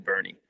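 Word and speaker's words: Boerne: We keep referring to Bernie, Texas, but Bernie actually looks like it's spelled Born. – Yeah. Everyone says Born. Yeah. – Everyone that doesn't know says Born, Texas. – It's Bernie. It's Bernie. Bernie, Texas.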